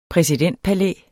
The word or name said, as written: præsidentpalæ